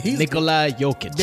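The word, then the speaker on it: Nikola Jokić: Nikola Jokic.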